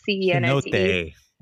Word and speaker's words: cenote: And if you do it right, there's some like really good combo, like Tulum and like Cenote. I don't even know if I'm saying that right. – Cenote. – Cenote, there it is.